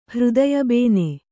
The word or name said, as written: ಹೃದಯಬೇನೆ